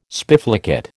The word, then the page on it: spiflicate: American English